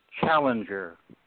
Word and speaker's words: Challenger: Okay, it's Bob Challenger. – How do I spell that? – Challenger. – C-H-A-L-L-E-N-G-E-R.